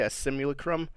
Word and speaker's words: simulacrum: That's how you say it, a simulacrum. I can't. Sure. That's how you actually say it.